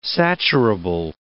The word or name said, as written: saturable